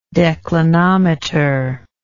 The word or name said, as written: declinometer